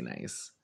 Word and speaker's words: Nice: It's not N-I-S-N-I-C-E. It's you guys actually say nice.